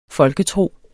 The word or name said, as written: Folketro